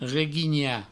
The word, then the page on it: reguinhar: reguigna